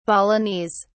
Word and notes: balinese: British English

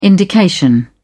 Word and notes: indication: British English

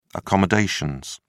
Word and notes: accommodations: British English

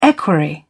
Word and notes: equerry: British English

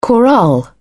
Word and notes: chorale: British English

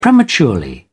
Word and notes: premature: American English

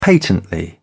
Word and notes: patently: British English

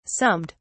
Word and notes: summed: British English

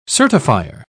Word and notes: certifier: British English

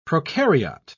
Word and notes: procaryote: English; British English

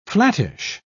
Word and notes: flattish: British English

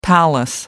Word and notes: palace: American English

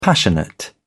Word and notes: passionate: British English

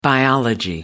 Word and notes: biology: American English

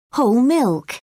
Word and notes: whole milk: British English

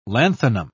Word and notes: lanthanum: British English

